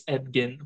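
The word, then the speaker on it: Edgin: Also yeah, it's we've been saying his name wrong the whole time. It's not Edgen. It's Edgen. – Ah, it's Edgen, apparently.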